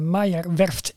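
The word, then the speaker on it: Meyer Werft: Voor onze donateurs, als exclusief uitje, heb je de mogelijkheid om met ons mee te gaan naar de Meijerwerft in Papenburg.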